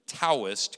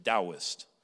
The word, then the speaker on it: Taoist: Now, if you've ever seen T A O, you might say Taoist, because that's the way we would do that in American English. But the right way to say it in Chinese is Taoist.